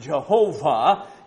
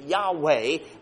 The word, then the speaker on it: Yahweh: When I was growing up, it was pronounced Jehovah. In more modern times, theologians, for various reasons, pronounce it Yahweh, but it is a particular name of the Lord.